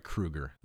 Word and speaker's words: Kroeger: But I actually got Mike Kroeger. I actually got Mike Kroeger on Dark Glass. – Wow. – I'm sorry, Kroeger. That's how you say the last name.